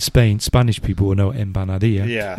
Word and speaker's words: Empanadilla: Empanadilla. – Empanadilla.